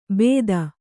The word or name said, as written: ಭೇದ